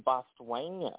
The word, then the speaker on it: Botswana: And Botswana is actually how you pronounce that.